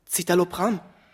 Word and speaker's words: Citalopram: Citalopram. – Ja, genau. Komisch, du hast das genau richtig ausgesprochen.